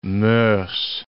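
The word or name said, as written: mœurs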